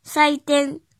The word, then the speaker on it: 祭典: 音读的时候读作“ 赛赛赛”，比如“ 祭典”“ 赛典”“ 赛典”“ 赛典”，写成日语汉字是“ 祭” 加字典的“ 典”，祭典。